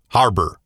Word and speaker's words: Harbour: David, how do you say his last name? Harbour. – David Harbour.